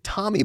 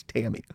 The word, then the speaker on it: Tammy: And they were pronouncing it Tommy Baldwin, like it's Tammy.